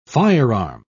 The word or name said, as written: firearm